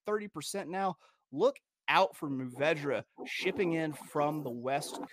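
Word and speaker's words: Mourvèdre: The number two for you non-wine snobs is pronounced Muvedra.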